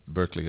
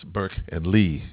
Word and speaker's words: Berklee: Berkeley is spelled B-E-R-K-L-E-E. It's not Berkeley as we may. It's Burke and Lee as two names together.